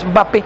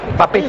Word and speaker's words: Mbappé: ¿Alguien alguna vez pronunció así, el tema este de Mbappé? – No, Mbappé. Alguien pronunció así, alguien de los colegas, y después otros caían en el mismo error. Es Mbappé, Mbappé.